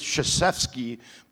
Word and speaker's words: Krzyzewski: Also, he's called Coach K because his name is Krzyzewski, but if you saw the spelling of it, you would never know. It starts with a K, which is silent.